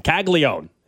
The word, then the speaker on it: Caglione: Yeah, because people are texting, if he's Italian, it's Caglione. I'm just telling you how his family calls their last name or says their last name, Caglione. And that's how they say it. That's how we're going to say it.